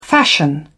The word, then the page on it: fashion: British English